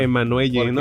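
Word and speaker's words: Emmanuelle: Emanuel con E al final. – Emanuel, ¿no? – Emanuel. – Pero sí se pronuncia Emanuel porque es nombre femenino. – Francés, ¿no? Es una madre.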